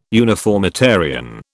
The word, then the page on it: uniformitarian: British English